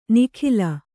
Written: ನಿಖಿಲ

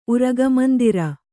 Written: ಉರಗಮಂದಿರ

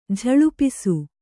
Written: ಝಳಪಿಸು

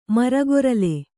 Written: ಮರಗೊರಲೆ

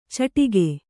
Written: ಚಿಟಿಕೆ

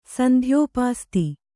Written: ಸಂಧ್ಯೋಪಾಸ್ತಿ

